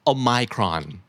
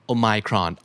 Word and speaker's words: Omicron: Omicron Omicron